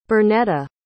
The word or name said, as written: berneta